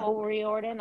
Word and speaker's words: O'Riordan: Dolores O'Riordan. Yeah. – O'Riordan.